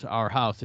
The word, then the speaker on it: Arhaus: which isn't spelled like two words, Our House. It's Arhaus, and it's a, you know, it's a big furniture store, right?